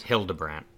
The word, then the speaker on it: Hildebrand: That E is throwing me off. Hillebrand. Hildebrand. Oh, yeah. it is. Yeah. It is pronounced Sam, with a D.